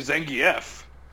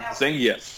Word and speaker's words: Zangief: And the few times that I've gotten past Ryu, Zangief, who I actually found out is actually Zangief, which kind of blew my mind. – Wait, is that how they pronounce it in the game? – That is how they pronounce it in the game. Zangief.